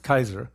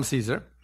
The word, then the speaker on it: Caesar: The Romans pronounced the C as a K, so it's Kaiser. But we call him Caesar.